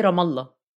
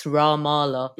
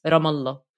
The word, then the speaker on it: Ramallah: In English, mainly, it's Ramallah. Because it's a long A, but it's rather a... It's rather a shorter A, so it's Ramallah.